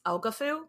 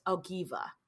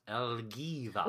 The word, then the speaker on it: Elgiva: Last time we pronounced this Elgafu, because that's how it looks when it's written. But I listened to and watched a YouTube video. I listened to a podcast about her and watched a YouTube video about her because I went down a rabbit hole. And it's pronounced Elgiva. – Elgiva. – Which is so much prettier than Elgafu.